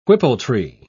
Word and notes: whippletree: British English

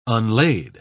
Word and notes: unlaid: British English

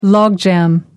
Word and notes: logjam: American English

